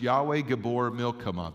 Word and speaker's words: Yahweh Gibbor Milchama: So the way that you pronounce this in Hebrew is mighty in battle is Yahweh Gibbor Milchama. That's the actual Hebrew pronunciation of this word.